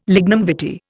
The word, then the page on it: lignum vitae: Indian